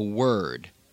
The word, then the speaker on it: word: There are three words, or perhaps more than three, where an O-R in American English is pronounced er, worm, word, worst.